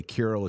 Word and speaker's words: Kirill: And by the way, Kirill is just the Greek or Russian pronunciation of Saint Cyril, how we say it in the West.